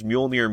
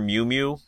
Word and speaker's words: Mjolnir: And just every time she calls Mjolnir Mew Mew, a little piece of me just wants to reach in the screen and say, just say it right. You know what it's called. – It's been said in dialogue by everyone else correctly.